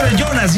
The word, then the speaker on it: Jonas: Jonas, ya sé, suena con Y, pero se escribe con J.